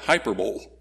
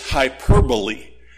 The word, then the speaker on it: hyperbole: Or some who don't know how to pronounce that call it hyperbole. Don't do that. It's hyperbole, also known as exaggeration.